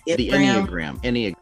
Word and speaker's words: Enneagram: Enneagram.